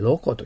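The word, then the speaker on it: local: ただ、発音に気をつけないと、多分ね、アメリカの人、で普通に発音するときはね、皆さんにロコと聞こえますよ。ロコ。だからカタカナで言うとロコ。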